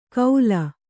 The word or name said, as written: ಕೌಲ್